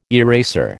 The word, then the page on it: eraser: American English